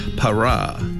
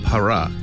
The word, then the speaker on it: Parah: 바라, 바라, P-A-R-A-H-A요. 바라. 한 번 더요. 파라.